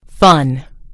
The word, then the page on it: fun: British English